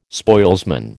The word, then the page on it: spoilsman: American English